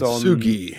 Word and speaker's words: Kintsugi: Kintsugi, Kintsugi, based Kintsugi.